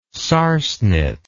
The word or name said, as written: sarsenet